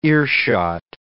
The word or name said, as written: earshot